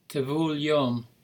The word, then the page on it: tevul yom: Hebrew